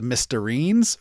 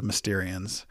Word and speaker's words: Mysterians: The Mysterians was probably the first one that I remember butchering horribly. I pronounced it the Mysterines for some reason.